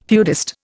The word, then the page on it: feudist: Indian